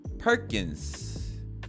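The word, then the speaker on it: Perkins: S-O-N-N-Y 그 소니 할 때 소니랑 스펠링이 똑같습니다 퍼킨스 퍼킨스가 더 맞겠네요 파킨스보다 퍼킨스 S-O-N-N-Y요 N 두 개예요 크게 될 놈이다 이름 합격?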